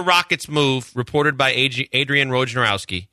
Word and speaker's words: Wojnarowski: Woj is Adrian Wojnarowski, just because I want to say that correctly.